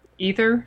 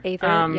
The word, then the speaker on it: Aether: I think it's Ether Apparel. A-E-T-H-E-R. Ether. – Ether, yep.